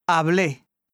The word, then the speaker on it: hablé: L'accentuation au passé simple. Les verbes à la première et à la troisième personne du singulier, au passé simple, sont accentués sur la dernière syllabe. Passé simple. – Hablé.